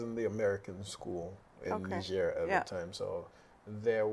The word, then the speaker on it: Niger: So, and you lived in Nigeria.